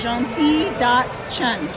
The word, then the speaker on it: Gentille Chhun: Gentille.chun.